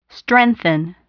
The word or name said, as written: strengthen